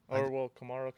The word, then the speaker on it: Kamara: I just think the playoff experience, Kamara, right? That's how you say it, Kamara? – Uh-huh. Kamara, same thing.